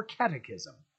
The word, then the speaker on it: catechism: You know, the way it is catechist or catechism. Because the CH is not CH in Latin or Greek, it is CH, like the Cairo. In fact, in Old Greek, the CH is actually spelled with an X, which is how you know it's CH. And I'm sorry, because that had to sound tremendously annoying as I was diving down that.